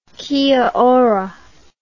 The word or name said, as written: kia ora